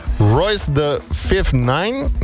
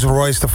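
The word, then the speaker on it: Royce da 5'9: En die zegt Royce the 5'9 met Boom, zeg ik dat goed? – Het is Royce the 5'9.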